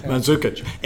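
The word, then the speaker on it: Mandžukić: Mandzukic.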